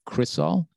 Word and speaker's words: Chrisol: And it's an artist based here in Los Angeles, a female artist by the name of Chrisol. And it's spelled Chris, like the person's name, C-H-R-I-S-O-L.